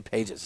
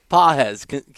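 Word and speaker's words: Pagés: We didn't even bring up the catch with Andy, I can't ever say his name, Pahe. I always want to say Pages. It's not his name. I know. – Andy Pahes. – Pahes, Cuban, I think, Cuban pronunciation.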